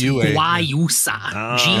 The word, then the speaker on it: Guayusa: It's pronounced Guayusa. G-U-A-Y-U-S-A.